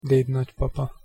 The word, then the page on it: dednagypapa: Hungarian